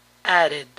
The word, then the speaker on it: added: Todo verbo que termina con el sonido de, al añadirle ed, este se pronuncia id, id. Noten que la D inglesa entre vocales suena como eri, added.